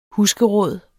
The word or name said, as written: husgeråd